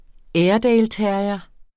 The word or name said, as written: airedaleterrier